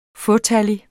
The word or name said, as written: fåtallig